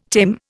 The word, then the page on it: tame: Indian